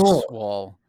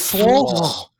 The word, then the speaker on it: Sewell: Is it Sewell? – It's basically Swall. – It's Swall.